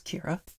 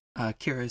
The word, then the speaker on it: Kyra: Hi, my name is Alice Kira. Kira is spelled K-Y-R-A, which is important because I'm going to be saying it a lot right now.